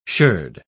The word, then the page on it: sherd: British English